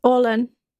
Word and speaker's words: Orlan: Their name was Orlin. – Orlin? – She nods. Though spelling it at home, O-R-L-A-N.